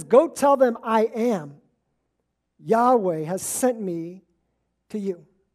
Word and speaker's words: Yahweh: Now, this is where we get the title that would later be pronounced as Yahweh.